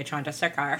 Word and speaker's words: Chandrasekhar: Director Jay Chandrasekhar. – Chandrasekhar, thank you.